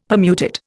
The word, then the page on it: Permutit: Indian